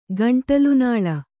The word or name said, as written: ಗಂಟಲುನಾಳ